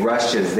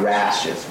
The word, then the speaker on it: rushes: I think because of the guide, the interviewers know you're trying to say rushes, they will understand. But try to be careful with the pronunciation. Rushes, not rashes. Rush, rush, rush, which is difficult for Japanese to say.